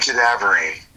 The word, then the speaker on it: cadaverine: Putrescine and Cadaverine, yes.